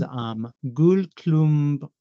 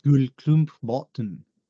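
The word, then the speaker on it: Gullklumpbåten: Yeah, the only thing that trips me up is the little A, the A with the little circle diacritic. I have no idea how you're supposed to say that one. How does that actually go? – It's an O, so you pronounce it like Gullklumpbaten.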